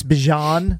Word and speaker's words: Bijan: Where he was at a table and they asked him, how do you pronounce your name? – It's Bijan. He says Bijan.